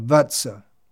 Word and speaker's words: Vatsa: Koto Botsa is Bengali pronunciation of Vatsa.